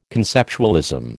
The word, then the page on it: conceptualism: American English